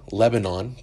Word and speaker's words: Lebanon: spelled like the country, Lebanon, but pronounced Lebanon.